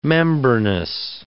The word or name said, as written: membranous